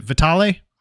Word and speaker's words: Vitale: Is it Vitale? – No, that's a great pronunciation.